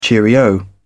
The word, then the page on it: cheerio: British English